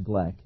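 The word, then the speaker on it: Glueck: Nelson Gleck, I believe that's the proper pronunciation of the name, G-L-U-E-C-K, I think is pronounced Gleck, who was a rabbi and a world-renowned archaeologist, as well as a spy for the British in World War II.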